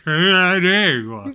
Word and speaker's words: Canandaigua: And now the correct pronunciation is Canandaigua. Canandaigua. She's like, what the fuck are you saying? I'm like, Canandaigua. She's like, it's just Canandaigua. What? I'm like, yeah, it's like Canada, but then gua at the end of it.